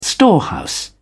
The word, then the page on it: storehouse: British English